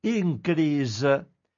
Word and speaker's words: INCREASE: Un esperimento di Citizen Science, Scienza dei cittadini, è previsto dal progetto europeo INCRIS, si scrive INCREASE Nato per salvaguardare e diffondere la diversità delle varietà di legumi alimentari.